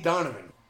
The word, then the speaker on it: Donavan: SD Donovan.